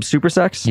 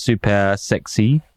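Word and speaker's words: Super Sexe: Club Supersex? – Yeah, yeah. Well, it's actually pronounced super sexy with like a Y.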